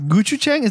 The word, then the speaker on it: gochujang: Gochujang.